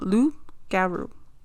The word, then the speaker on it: loup-garou: In French folklore, the werewolf is called the loup-garou. Did I say that? – Loup-garou? Yeah.